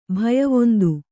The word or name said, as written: ಭಯವೊಂದು